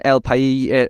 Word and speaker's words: El País: And I read it in El País is how you're supposed to pronounce it.